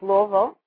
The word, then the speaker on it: Louisville: So we used to go to Louisville, that's how it's pronounced, Louisville, see my grandparents, and go to a store that sold kosher frozen meat from Philadelphia on Sunday.